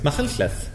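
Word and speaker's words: Machynlleth: McCunclith.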